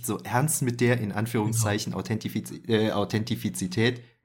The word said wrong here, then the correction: Authentizität: Nee, jetzt habe ich falsch ausgesprochen, ne? Warte mal. Authentizität.